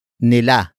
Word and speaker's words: nila: The next word is nila. Nila. Nila. Nila is spelled as N-I-L-A.